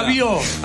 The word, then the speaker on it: Rabiot: La T no se pronuncia. – Rabiot. – Ahí está.